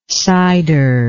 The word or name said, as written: cyder